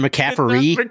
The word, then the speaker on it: McCaffery: Is it McCaffery? There's no T? Yeah. I don't know why. Yes, I keep putting a T in there.